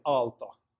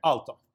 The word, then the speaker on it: Aalto: Aalto. Famous architect. – Got it. But anyone that didn't speak Finnish couldn't pronounce. What's the name in Finnish? – No, it's just Aalto. – Okay. – Even in Finnish.